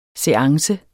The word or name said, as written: seance